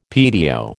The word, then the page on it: PTO: American English